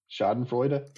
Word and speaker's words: Schadenfreude: how do you say it? – Schadenfreude. – If you like Schadenfreude. – I think you did it right the first time.